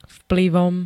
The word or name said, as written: vplyvom